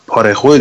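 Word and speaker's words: Parejo: پاره رو نگه داشتن پارخو دیگه اسمش فکر کنم تلفظش درستش پارخو پارخو درست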